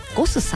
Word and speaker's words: GOSS: ゴスさん、 GOSS と書いてゴスさん、そしてお寿司屋さんが 2店舗ございます。